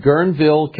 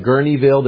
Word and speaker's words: Guerneville: It's called Guerneville, California. Some say Gurneyville. There was two pronunciations on Wikipedia when I looked it up. Guerneville or Gurneyville, California, that is in the same location or close by the Bohemian Grove.